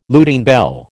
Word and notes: Lutine bell: American English

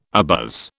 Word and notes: abuzz: British English

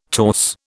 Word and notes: torse: British English

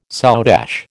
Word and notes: soutache: American English

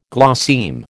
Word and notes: glosseme: American English

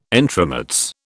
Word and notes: entremets: British English

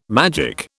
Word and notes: magic: British English

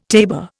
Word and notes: tabor: Indian

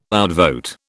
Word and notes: outvote: British English

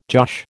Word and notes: josh: American English

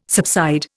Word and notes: subside: Indian

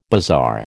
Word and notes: bazaar: American English

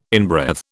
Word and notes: inbreathe: British English

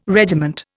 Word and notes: regiment: Indian

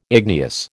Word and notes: igneous: American English

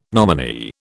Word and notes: nominee: British English

